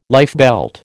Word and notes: lifebelt: American English

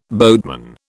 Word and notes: boatman: British English

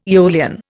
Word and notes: aeolian: Indian

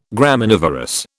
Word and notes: Graminivorous: British English

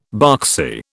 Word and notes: boxy: British English

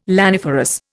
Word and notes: laniferous: Indian

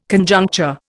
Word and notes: Conjuncture: Indian

